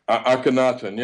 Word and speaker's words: Akhenaten: Is, I think you pronounce it Aten? Is it Aten? The Egyptian Akhenaten. Yeah. – Yeah, Akhenaten. That's it.